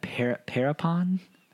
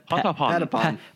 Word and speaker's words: Patapon: And we have how would you say that? Parapon? – Patapon.